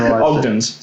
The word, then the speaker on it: Ogden's: Did I not say Ogden's? – I think you went with Odgen's. – Ogden's. Sorry. – Because it makes much more sense if you say it correctly.